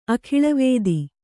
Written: ಅಖಿಳವೇದಿ

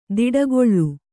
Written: ತೋಟಿಗೊಳು

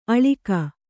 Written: ಅಳಗೆ

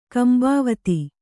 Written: ಕಂಬಾವತಿ

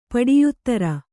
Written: ಪಡಿಯುತ್ತರ

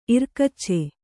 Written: ಇರ್ಕಚ್ಚೆ

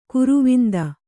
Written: ಗರ್ವಾಂಧ

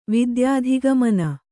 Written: ವಿದ್ಯಾಧಿಗಮನ

